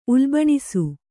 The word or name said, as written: ಉಲ್ಬಣಿಸು